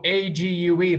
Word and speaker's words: ague: Like, oh, oh, A G U E, that should rhyme with plague, right? Yeah. Nope, nope it's ague.